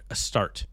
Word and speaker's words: Astarte: Hence, I feel like I've been saying that wrong the whole time. I've been saying a stare, a start. Sorry, I've been butchering that the whole time a, a start.